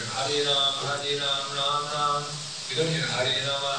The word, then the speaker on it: Rama: In Sanskrit, the pronunciation must be Rama. In Hindi, you can say Ram. And even the Sanskrit people will clip that last A pretty short sometimes. Right? It's two syllables. Properly it's Rama. But you'll hear it clipped.